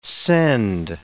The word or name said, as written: scend